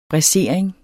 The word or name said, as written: braisering